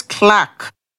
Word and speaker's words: clerk: Clerk is pronounced as clack.